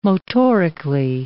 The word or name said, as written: motorically